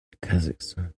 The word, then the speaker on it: Kazakhstan: Kazakhstan. – Kazakhstan. – Kazakhstan, sorry, I added a syllable.